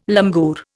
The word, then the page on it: langur: Indian